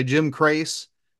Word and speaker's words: Jim Crace: So I'm going to say Jim Crace. – Yeah, and is that how to say it? – Yes, that's correct.